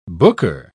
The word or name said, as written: booker